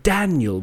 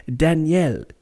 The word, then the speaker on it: Daniel: So for us, we would have, for example, the English, we call people Daniel, with a stress on the first Dan, Daniel, yes, Daniel. But in French, you have the same name, but it'd be Daniel, Daniel, even for a boy, Daniel, because it's exactly the same.